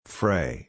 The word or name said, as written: fray